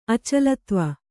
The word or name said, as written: ಅಚಲತ್ವ